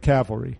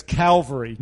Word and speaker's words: cavalry: No, he's saying it wrong. He's saying it as Calvary. No, that's how he always want to say it and screw it up. It's cavalry. It's cavalry.